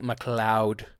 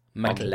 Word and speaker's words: MacLeod: How is that pronounced? – I believe it's pronounced MacLeod, like the man in Highlander, MacLeod. – MacLeod.